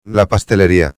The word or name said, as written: la pasteleria